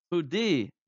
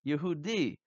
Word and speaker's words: Yehudi: How do you say Jew in Hebrew? Yehudi, Yehudi.